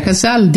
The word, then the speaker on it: Cazale: Non so se si pronuncia Cazale, ditemelo voi. – Sì, è giusto.